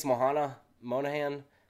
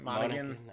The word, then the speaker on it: Monaghan: Monahan. How do you say his name? The guy that plays the Joker in the Batman series and also Shameless. – Cameron Monaghan. – Okay. – Something like that.